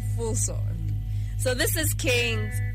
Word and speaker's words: Kheengz: So he goes by the name Kings, except it's spelled K-H-E-E-N-G-Z.